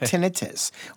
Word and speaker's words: tinnitus: Pronunciation, there are two different pronunciations, and they're both correct. I mean, tinnitus is used by some people. Tinnitus are used by others, but the Brits say tinnitus. – Of course. – And the Americans, of course, right. That's right. As you know, those Brits. – Those Brits. – Anyway, that's right. But it's pronounced both ways.